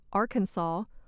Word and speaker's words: Arkansas: Moving inland, we come to a state that does not sound as it is spelled. – Number 5, Arkansas. The name Arkansas means south wind. It comes from a word used by some Native Americans to describe an early tribe in the area. The pronunciation Arkansas follows the French custom of not pronouncing the final S.